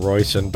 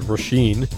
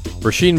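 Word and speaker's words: Róisín: We keep calling her Royson, but the label told us it's Roisin. Roisin Murphy. Maybe we've always said it wrong, huh? – Yeah.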